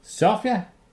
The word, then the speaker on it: Zofia: Sofia. Var det sådan, det blev udtalt? – Ja, ja. – Z-O-F-I-A.